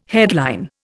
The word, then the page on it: headline: Indian